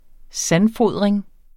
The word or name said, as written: sandfodring